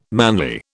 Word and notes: manly: British English

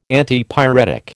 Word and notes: Antipyretic: American English

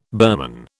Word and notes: Burman: British English

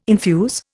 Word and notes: infuse: Indian

